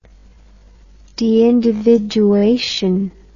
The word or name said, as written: deindividuation